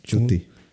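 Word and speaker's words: Ncuti: darle una oportunidad a Cuti. – Chuti. – Chuti se pronuncia.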